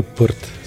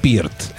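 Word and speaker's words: Peart: Ами, не бих казал, че Ръж са имали въздействие, но Нил Пърт със сигурност е имал. – Гледах отново много начини, в които го представяте интервюта. Всъщност всички в Канада му викат Нил Пиърт.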